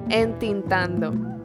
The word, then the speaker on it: entintando: So, inking would be tinteando? – Casi. – What is it? – Entintando. – Entintando. – Yeah, I know. I really didn't know that one either. – Entintando sounds like intentando.